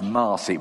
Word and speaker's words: Mercy: Now, this is Pastor Mong and his wife Mercy, which is pronounced Marcy, which is a bit confusing because my associate at Garstang is called Marcy.